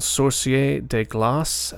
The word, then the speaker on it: Sorcier de Glace: The band is called Sorcier de Glace, and at least that's how I believe it's pronounced in French,